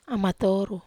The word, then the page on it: amatoru: Wamesa